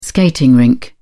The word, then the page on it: skating rink: British English